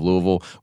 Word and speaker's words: Louisville: We said that right. Louisville, Kentucky.